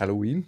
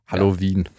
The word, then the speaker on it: Halloween: Ja, wie spricht man es denn aus? – Halloween. – Mhm, okay. – Jetzt also eher drin. Englisch. Halloween.